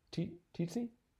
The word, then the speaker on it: tsetse: You know, and I was thinking, it's been such a long time since, let's see, how's it pronounced? tsetse? Uh, tsetse fly, right? – tsetse fly. – tsetse? – That's how I say it.